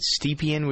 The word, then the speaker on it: Stepien: This is the story of Jenny, is it Stepien? Would you pronounce it that way, do you think? – That sounds about right, yeah.